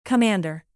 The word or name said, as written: commander